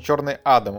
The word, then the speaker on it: Адам: А, на Википедии написано Черный Адам. Ударение на А, на первом.